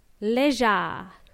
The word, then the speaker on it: leisure: It's spelt with seven letters and we only pronounce four. Leisure. And it's spelt as L-E-I-S-U-R-E.